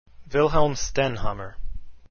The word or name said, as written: Stenhammar